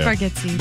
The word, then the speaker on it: Bargatze: I think it's Bargatze.